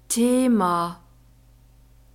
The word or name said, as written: thema